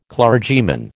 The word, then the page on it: clergyman: American English